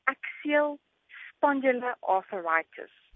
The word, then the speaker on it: axial spondyloarthritis: First of all, what is atrial spondyloarthritis and what are its causes? – Okay, so the correct pronunciation is axial spondyloarthritis.